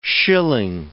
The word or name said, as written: shilling